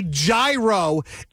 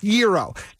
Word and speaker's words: gyro: If somebody in my proximity orders a gyro, it makes my skin crawl. And I go, just so you know, it's pronounced gyro.